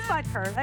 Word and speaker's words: Butker: It is Butker.